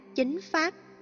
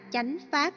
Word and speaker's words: chánh pháp: Ví dụ, phiên âm chuẩn là chính pháp, nhưng miền Nam không ai gọi là chính pháp, mà là chánh pháp.